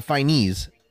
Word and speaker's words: Fiennes: Ralph Fiennes, Fiennes, that's how you pronounce his name, playing Voldemort here.